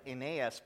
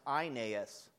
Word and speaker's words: Aeneas: First is the healing of, it looks like Aeneas, but it's pronounced Aeneas.